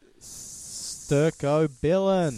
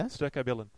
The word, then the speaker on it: Stercobilin: Into Stercobilin. – Is that how you pronounce it? – Yeah. – Stercobilin, yeah, which is what makes your poo brown.